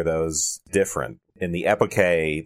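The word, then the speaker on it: epoché: So let's talk about the bracketing, the epike. Is that how it's pronounced? That's how it's pronounced in the ancient Greek. – Yes.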